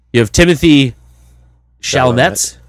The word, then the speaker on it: Chalamet: Chalamet, I think is what it's pronounced, I think. – Chalamet, yeah, for Call Me By Your Name.